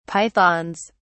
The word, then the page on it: pythons: British English